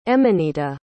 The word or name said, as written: emanita